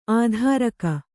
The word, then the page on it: ಅದುರುಗ: Kannada